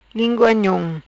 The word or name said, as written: Lingua Nhom